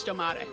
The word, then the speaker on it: Stormare: Also, we're all probably saying his name wrong. I think it's Stomare.